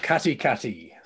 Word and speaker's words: Katikati: It's called Katikati. – Katikati.